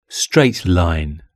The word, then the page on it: straight line: British English